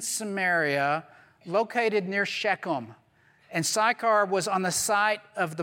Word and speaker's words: Sychar: But Sychar, and if you want to pronounce it in the Hebrew way, you'd say Sukkar.